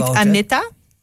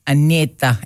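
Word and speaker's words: Anitta: Zeg je dan niet Anita? – Anita. Ik denk Anita. Het wel. Want je moet het ook op zijn Braziliaans uitspreken.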